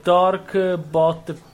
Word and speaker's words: Dorkbot: Eh, come, come, diavolo si legge sta roba? Dobo. – È... Dork. – No, è Dorkbot PD.